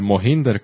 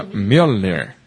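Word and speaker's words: Mjölnir: Ver a Thor luchando, haciendo girar su martillo, el Mohinder, como dice Marmán. – El Mohinder. – Pero se pronuncia Mjolnir.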